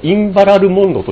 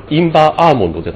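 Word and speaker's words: Inveralmond: あ、それから、これはどうも多分このインバラルモンドと読むらしいです。インバーアーモンドじゃなくて。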